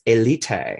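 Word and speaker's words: Élite: But you know, some people say the correct pronunciation is Elite. Because it does probably take place in Spain.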